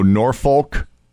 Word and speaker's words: Norfolk: It doesn't actually rhyme with the word folk because it's spelled N-O-R-F-O-L-K. So Norfolk, Virginia. It's Norfolk. – Norfolk? – Norfolk.